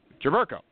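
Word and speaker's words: Jerebko: Jaberko.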